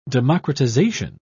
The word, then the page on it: democratisation: British English